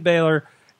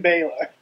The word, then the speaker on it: Balor: Finn Balor.